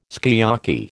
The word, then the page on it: sukiyaki: American English